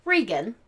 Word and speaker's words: Reagan: Spelled Reagan, pronounced Regan.